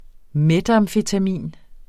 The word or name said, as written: metamfetamin